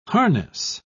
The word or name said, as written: harness